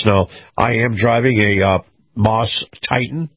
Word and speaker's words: Maus: Now, again, the Truckers Highway and Byway Report is brought to you by Moss Family Automotive. Moss is spelled M-A-U-S, Family Automotive.